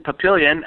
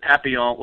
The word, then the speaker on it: Papillion: Actually, I'm in Papillion. I think you guys said Papillon, which is the correct way to spell it or pronounce it, but we in Nebraska, we're just Papillion.